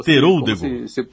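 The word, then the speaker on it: Teroldego: Como Teródego. – Uma uva italiana, Teródego. Como se pronuncia, não tem nenhuma letra dobrada.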